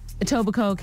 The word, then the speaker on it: Etobicoke: T-U-H-R-O-N-N-O. – Yeah, exactly. – Toronto. – Toronto. – Yeah. – Etobicoke came in at number 3, not surprisingly. K is silent.